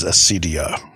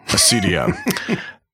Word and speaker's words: acedia: I think the pronunciation is Asidia. – Asidia.